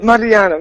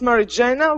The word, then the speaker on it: Mariana: Mariana, very good. Great Mariana. Pronunciation. Usually I pronounce Mary Jana, which is American pronunciation, but okay.